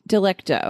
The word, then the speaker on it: Delecto: Delecto or Delicto? – Delecto. – Okay. – Yeah. And he goes by... – That's the continental pronunciation.